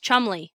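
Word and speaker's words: Cholmondeley: Well, that's where British intelligence officer Charles, and I'm going to spell this out because this is ridiculous. Chomondeley. If that was a spelling word, I would fail the spelling bee, especially because that long word is just pronounced Chumley.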